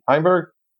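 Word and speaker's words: Heimburg: Oh, it's got to be, I want to say Hamburg. How do you say his name again? – Heimberg.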